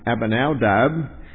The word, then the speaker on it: Abinadab: Abinadab is how you pronounce that.